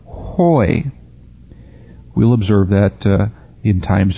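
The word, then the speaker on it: hoi (οἱ): The rough breather is pronounced as though you put in letter H at the beginning of the word. So instead of saying oi, it would be pronounced correctly hoi, the h sound, the H sound.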